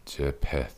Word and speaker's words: Jephthah: Jephthah. – Jephthah.